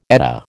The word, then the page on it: Edda: American English